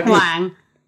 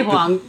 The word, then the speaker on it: Huang: It's pronounced Heather Huang. – Huang. Okay. – But I don't really care. I say Huang. That's how I introduce myself to Americans.